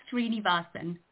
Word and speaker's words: Srinivasan: It's Sapna Srinivasan.